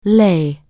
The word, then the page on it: lie: anglais